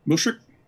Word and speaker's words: Mushrik: let me know if I pronounce this right, Musirik, M-U-S? – Mushrik? – That's right. Thank you.